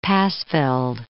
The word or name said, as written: Passfield